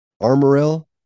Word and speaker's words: Armorel: Very, Amarel, is that how you pronounce it? – I'm sorry. – Not being Southern, I don't know. – What was it? – Amarel? – Armorell? – Yeah, it's Armorel. – I think it is Armorell.